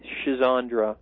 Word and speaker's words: Schizandra: The most common spelling is S-C-H-I-Z as in zebra, S-C-H-I-Z-A-N-D as in David, R-A, schizandra. – So it's a little bit like schizandra. – Something like that, right. C-H-I-Z-A-N-D-R-A.